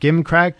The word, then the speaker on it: gimcrack: Rob, you must know this word. Gimcrack, G-I-M-C-R-A-C-K? – I have heard this. – Soft G. – Oh, it's Gimcrack.